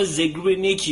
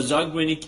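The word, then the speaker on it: Zeigarnik: زاگرنیک این یه تئوری مطرح میکنه بالا زگرنیک یا زاگرنیک ایفکت خیلی حالا تلفظهای مختلف هست در موردش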